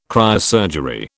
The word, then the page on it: cryosurgery: British English